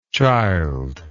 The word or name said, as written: childlessness